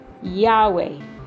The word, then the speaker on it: Yahweh: So, the name that God has given me, the second name, then this name is pronounced Yahweh. Yahweh. And it is spelled YAHWEH, Yahweh.